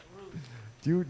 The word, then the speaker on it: Rush: That's how you say it, Roosh.